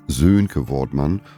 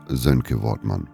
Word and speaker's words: Sönke Wortmann: Es ist nämlich nicht Sönke Wortmann, sondern Sönke Wortmann.